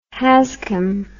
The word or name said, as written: Hazchem